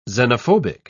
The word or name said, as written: xenophobic